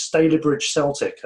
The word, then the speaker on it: Stalybridge Celtic: It was Stalybridge Celtic, as it's apparently pronounced.